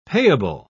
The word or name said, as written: payable